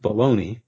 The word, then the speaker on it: Bologna: That G there is to make the N into an, what the Spanish call an ñ, ñ sound. The sausage is named after the city, but we pronounce it Bologna. And often spell it B-A-L-O-N-E-Y, and both of those are generally acceptable.